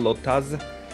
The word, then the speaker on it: Lottaz: You can Google my name, Pascal Lottaz, L-O-T-T-A-Z, although pronunciation is Lotta, but L-O-T-T-A-Z.